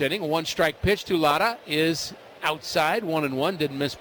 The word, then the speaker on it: Lara: And we're saying Lotta, but it's spelled L-A-R-A.